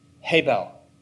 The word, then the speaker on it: hebel: And we anglicize the spelling and the pronunciation, but what's a famous Old Testament name that sounds an awful lot like hebel? Abel!